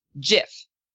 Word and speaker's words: GIF: The creator says he wants it to be pronounced GIF.